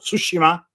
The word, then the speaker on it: Tsushima: Dat was mijn goede streven en dat begon op 1 januari met het voltooien van Ghost of Tsushima.